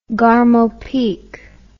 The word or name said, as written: garmopeak